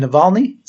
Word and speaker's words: Navalny: Look at, what's his name, Navalny? Is that how you pronounce it? Yeah. I think we spoke about him before.